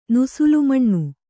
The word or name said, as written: ನುಸುಲುಮಣ್ಣು